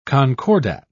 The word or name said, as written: concordat